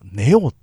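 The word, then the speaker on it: 愛桜: 佐藤さんのねこう愛と桜と書いて何と読むみたいなね、あ、あい、あいおみたいなね。あ、あなんかな、なんだろうと思った。ネオって読むんですって。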